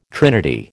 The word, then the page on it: trinity: American English